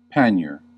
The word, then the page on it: pannier: American English